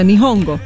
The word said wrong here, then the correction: Nyong'o: Nyong'o. – Nyong'o.